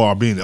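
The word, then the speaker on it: Arvydas: How do you say his name, his first name? – Arbenas. – Oh, Arbenas. Okay.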